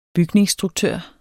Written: bygningsstruktør

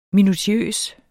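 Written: MINUTIØSE

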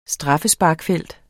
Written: straffesparksfelt

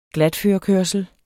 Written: glatførekørsel

